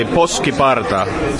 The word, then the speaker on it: Poskiparta: Exactly, Poskiparta. – See, now why couldn't I have said that? – Yeah, Finnish language is totally phonetical. So everything you see, you pronounce it exactly how it's written.